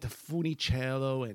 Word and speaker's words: Funicello: And by other stuff like Annette Funicello and I pronounced that right, Frankie Avalon.